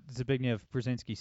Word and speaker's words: Zbigniew Brzezinski: And, like, before we move on, I just have to mention, you know, with what Zbigniew Brzezinski said, super hard name to pronounce, by the way. – Yeah, dude, congrats on nailing that each time. – I think I nailed it twice in a row.